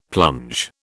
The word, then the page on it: plunge: British English